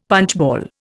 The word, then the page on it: punchball: Indian